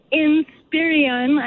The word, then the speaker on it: Inspiron: And the Black Friday models seem to be the Dell Inspiron.